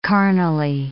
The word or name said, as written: carnally